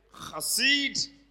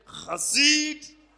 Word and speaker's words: Chassid: So look at them and say, Chassid. Now it's going to look like you want to come up with some phlegm, but no. That's the way it is pronounced, okay?